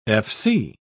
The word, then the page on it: fc: British English